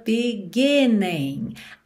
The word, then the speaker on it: beginning: I feel like this is the beginning. Aqui, por conta da música, a gente tem beginning, mas normalmente essa palavra teria a sílaba tônica na segunda sílaba, ok? Beginning, beginning.